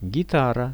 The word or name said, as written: gitara